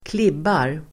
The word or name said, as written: klibbar